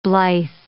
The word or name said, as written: blithe